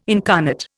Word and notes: incarnate: Indian

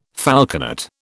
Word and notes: falconet: British English

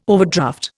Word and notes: overdraft: Indian

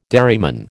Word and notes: dairyman: American English

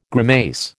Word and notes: grimace: American English

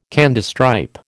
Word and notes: Candystripe: American English

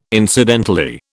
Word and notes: incidentally: British English

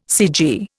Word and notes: cg: Indian

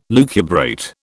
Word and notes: lucubrate: British English

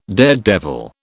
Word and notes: daredevil: British English

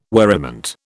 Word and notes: worriment: British English

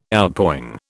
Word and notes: Outpouring: British English

